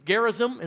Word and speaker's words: Gerizim: our own house of God on Mount Gerizim is how you say it.